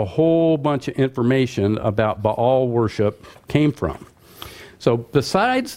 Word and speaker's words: Baal: The word Baal, or in Semitic language, it's just B-L. And, you know, the A's are the dots and the tittle, so to speak. So it's Baal is the way you pronounce it.